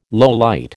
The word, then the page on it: lowlight: American English